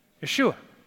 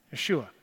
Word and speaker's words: Yeshua: Anybody here know how you would pronounce Joshua's name in Hebrew? Anybody know? Say it out loud if you think. Yeshua, right? Yeshua.